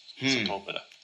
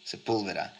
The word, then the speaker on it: Sepúlveda: Sepúlveda. There's actually an accent on the U, Sepúlveda.